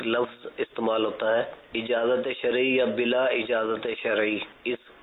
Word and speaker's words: شرعی: ہمارے شرعی تلفظ شرعی ہے, شرعی یہ اہل علم بول رہے ہوتے, شرعی. را پر زبر نہیں ہے, را ساکن ہے, اس پہ جزم ہے.